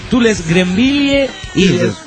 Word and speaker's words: Greenville: Pero esa aclara, Greenville, pronunciación. – Greenville.